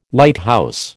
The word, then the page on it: Lighthouse: American English